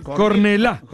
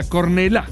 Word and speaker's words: Cornellà: Cornellà. – ¿Qué? – Con acento en la Cornellà.